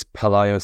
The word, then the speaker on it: Pelayo: And then also Pilau? How do you pronounce it? – I believe it's Pelayo Sanchez.